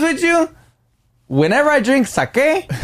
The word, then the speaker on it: sake: We did the TikTok on the sake, but we need to correct ourselves because a lot of people telling us that you don't say it like that. You don't say sake. – Okay. – Say sake.